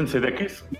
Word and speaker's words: Sudeikis: Sadikis. ¿Cómo chucha se pronuncia? Jason Sudeikis.